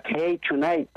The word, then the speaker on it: Hey Tonight: It's on Hey Tonight. I pronounced it wrong.